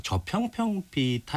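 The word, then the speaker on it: 저편평비: 발음이 좀 어려운데 저평평비. – 네. 네. 맞아요.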